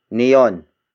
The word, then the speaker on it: niyon: And then finally, we have neon. Neon. Neon. Neon is spelled as N-I-Y-O-N. It means that, but far from both of us. Again, that's neon.